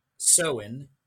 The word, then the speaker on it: Samhain: According, of course, as you said, to Irish folklore, this Samhain, as we pronounce it, can be traced all the way back to ancient Celtic tradition.